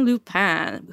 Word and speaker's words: Lupin: So it's a retelling of a classic French story with which I was completely unfamiliar about Arsène Lupin, who is a world famous gentleman thief and a master of disguise. – And I'm going to spell that for our listeners. L-U-P-I-N.